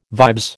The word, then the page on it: vibes: American English